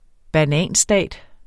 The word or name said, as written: bananstat